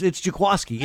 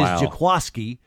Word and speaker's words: Jaquiski: No, it's Jaquaski. It's spelled Jaquiski. it's spelled Q-U-I-S-K-I, but it is Jaquaski, and the 49ers folks finally got it right.